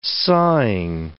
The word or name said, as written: sawing